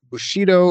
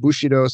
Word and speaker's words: Bushido: This came from the old samurai warrior ethic, Bushido, or I've heard it pronounced Bushido sometimes.